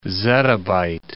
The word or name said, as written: zettabyte